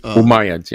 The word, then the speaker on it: Umayyads: The Umayyads, how do you say that name? – Umayyads, yeah.